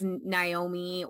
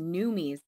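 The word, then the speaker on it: Noomi: For a long time, I thought it was Naomi or Nuami or something, but no, it's Numi as far as I know.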